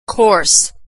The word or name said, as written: course